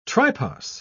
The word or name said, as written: tripos